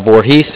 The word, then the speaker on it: Voorhees: Box 90, looks like Voorhees, did I say that right? – Yep.